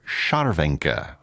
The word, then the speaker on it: Scharwenka: Szarwenka. I'll spell that last name for you. S-C-H-A-R-W-E-N-K-A.